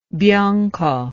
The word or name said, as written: bianco